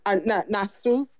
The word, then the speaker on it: Naksu: So the thing that humanized him for me and like, you know, kind of smoothed out his rough edges was Nasuk, you know, was, why am I saying her name wrong? Naksu.